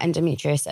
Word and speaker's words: endometriosis: I wanted to talk to you about endometriosis.